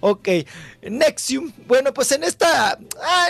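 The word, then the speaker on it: NXIVM: ¿cómo se dice? Nixum. – Nexium. ¿O ¿Cómo se llama? – Nexium. Nexium.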